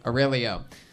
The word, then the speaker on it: Aurelio: All right, stick with me over here, hey, Aurelia. Am I saying that right? – It's actually Aurelio. – Aurelio.